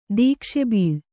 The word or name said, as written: ದೀಕ್ಷೆಬೀಳ್